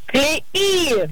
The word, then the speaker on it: клеи: В данном слове во множественном числе ударение всегда падает на последний слог. Поэтому последнее. – Правильно говорить еще раз. Как? – Клеи!